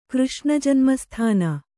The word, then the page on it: ಕೃಷ್ಣಜನ್ಮಸ್ಥಾನ: kannada